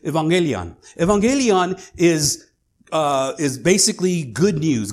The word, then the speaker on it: evangelion: The good news, they would say evangelion. That's how they would pronounce it.